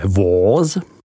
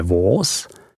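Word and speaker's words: Vosges: Za to vděčí pohoří Vogéze, vyslovujeme Vóz, chránící region ze západu, na jehož úpatích a svazích nalezneme všudy přítomnou vinnou révu.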